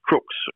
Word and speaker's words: Crux: Its correct name is Crux, or you could pronounce it Crux, so like a crucifix.